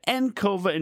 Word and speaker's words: Encova: Joining with us today, we have Eric Harmon, the VP / business director of an Encova Insurance.